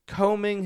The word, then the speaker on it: combing: No B sound. The B is silent. Combing.